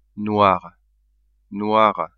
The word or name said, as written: noir, noire